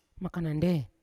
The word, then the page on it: makanande: Yawa